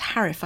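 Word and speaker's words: Haruf: The author is called Kent Harif, I think is how you say his surname. It's H-A-R-U-F.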